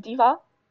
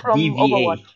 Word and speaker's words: D.Va: Suit, you mean D.Va? – Yes. Oh, so that's how you pronounce it. I just said like D.V.A. Overwatch. Overwatch. – D.V.A. You pronounce it D.Va.